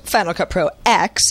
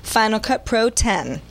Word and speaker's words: Final Cut Pro X: Hey guys, after all my talk of Final Cut Pro X, that was how he chose to say it, being pronounced Final Cut Pro X, it turns out that's wrong. Apple told me today at its London headquarters, it's pronounced Final Cut Pro 10.